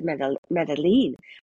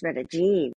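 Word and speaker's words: Medellín: I always said Medellin, but it's Medellin.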